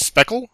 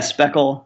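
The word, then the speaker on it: Speclj: As far as an Eighth Light, if I'm not misunderstood, that's pronounced Speckle. It's S-P-E-C-L-J. – Yeah, the CLJ is kind of a joke. I don't really remember who came up with that, but it was, yeah, Speckle.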